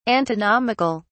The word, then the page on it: antinomical: British English